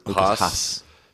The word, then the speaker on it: Haas: Lucas Hans is kind of like the retarded. – Haas. – Is the, I was just about to make fun of him, and I said his name wrong. – Yeah. – Anyway, Lucas Haas is right.